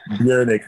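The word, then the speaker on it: Bjrnck: I don't even want to give the name out of the project yet, but Bjernick. Her name is spelled B-J-R-N-C-K. B-J-R-N-C-K, Bjernick.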